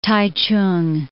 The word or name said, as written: Taichung